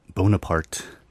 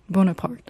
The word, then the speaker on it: Bonaparte: 아, 베토벤은 원래 이 교향곡을 프랑스의 나폴레옹에게 바치려고 했기 때문에 원래 붙인 제목은 이거 뭐라 어떻게 발음해야 되죠? – Bonaparte. – Bonaparte.